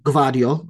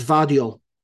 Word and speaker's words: Gvardiol: Listen, I was calling him Gvardiol, right? And my Croatian friend said it's a silent G and it's Vardiol.